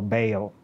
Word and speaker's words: Baal: For this goddess, she is a partner, lover of God Baal, or Baal, as it is pronounced in America sometimes, and plays quite prominent role in Ugaritic mythology.